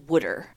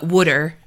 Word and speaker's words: water: Also, BTW, in my area, we pronounce it as wooder. – Not water, but wooder.